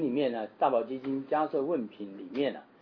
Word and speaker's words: 迦叶: 啊，大宝基金里面这部经里面有一品啊，叫做迦摄问品。迦摄佛就是这个 Kashyapa，前面第一个字 Kashyapa，迦是释迦牟尼佛的迦，摄其实是叶子的叶，啊，念成摄，就是我们一般说迦叶佛啊，其实是迦摄佛了，迦摄。